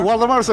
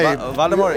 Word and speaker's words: Waldemar: Waldemar's okay. Waldemar. It's an extraordinary name. Usually in my country, I born in Uruguay, it's Waldemar.